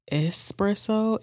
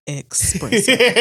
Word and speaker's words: espresso: I want y'all to know that the word is espresso and not expresso. I really want to drive that in. It is spelled E-S-P-R-E-S-S-O. There is not a single X in that bitch.